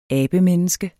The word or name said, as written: ABEMENNESKET